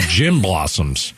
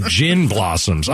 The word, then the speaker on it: Gin Blossoms: No, it's weird because when I looked it up today, I typed in J-I-M. I thought it was the Jim Blossoms. For all these years, I didn't know it was the Gin Blossoms. I thought it was the Jim Blossoms. It was spelled with a J-I-M like the name.